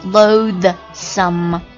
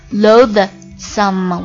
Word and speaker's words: loathsome: 下一个单词也是歌词当中出现的，也有一个 some 作为词尾在后面，叫做 loathsome，要说 loathsome， loathsome。我现在发音不标准的，我是要把那个的字念出来让大家听到，但是标准发音就是做那个动作 loathsome，你根本听不到那个的音 loathsome，形容词表示令人憎恨的或者让人讨厌的、厌恶的。